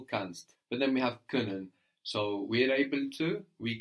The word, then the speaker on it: Können: Können, good, with the O, with the umlaut. Können, pronounced like the vowel in bird.